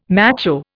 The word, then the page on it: macho: Indian